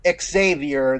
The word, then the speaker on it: Xavier: He's Xavier, they're just Xavier. – Mm-hmm. – You don't pronounce the X. You pronounce the X like a Z.